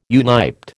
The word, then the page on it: uniped: American English